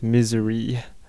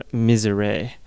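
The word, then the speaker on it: Misère: Welcome Back, that was One Big Lie with Take It All Away, and before that we had the Crumbums with Misery, or Misere, is how they pronounce it.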